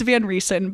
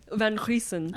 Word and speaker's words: Van Riesen: Is that how you say your last name? – It's Van Riesen, but I like Megan's pronunciation better. – Wait, say it again. – Van Riesen.